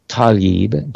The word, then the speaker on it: Tlaib: Now, meanwhile, we have Representative Rashida Tlaib, and I confirmed the pronunciation of her name shortly before the program. Rashida Tlaib, Democrat of Michigan, wrote an op-ed.